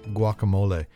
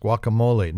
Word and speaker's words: guacamole: Now, what Super Bowl party, what sporting event party wouldn't be complete without guacamole? And people, please, it's pronounced guacamole, not guacamole. I have heard somebody call it guacamole.